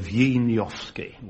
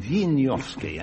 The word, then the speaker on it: Wieniawski: No, it's a Scherzo Tarantella by Wieniawski. – Yes, or pronounced now, I'm told, Wieniawski. We've now decided to call him Wieniawski.